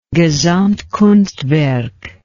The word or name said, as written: gesamtkunstwerk